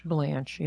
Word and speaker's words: Blanche: I'm assuming this is pronounced Blanche. – Blanche, yes.